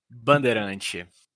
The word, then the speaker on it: Bandeirante: The plane is an Embraer EMB-110 Bandeirante, or it's probably pronounced like this in Brazilian. – Bandeirante. – Yeah, I nailed it, didn't I?